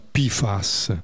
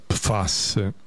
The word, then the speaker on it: PFAS: Dai, vi parlo dei PFAS, che in inglese si pronuncia PFAS, ma in italiano si pronuncia PFAS. Mi sono informato e questa è la pronuncia corretta, PFAS.